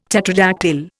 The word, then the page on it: tetradactyl: Indian